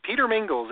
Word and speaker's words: Peter Mingils: Just Google yeah, Google my name, Peter Mingils, M I N G I L S.